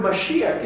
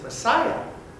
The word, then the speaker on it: Mashiach: In fact, the word Mashiach is used. We just pronounce it Messiah.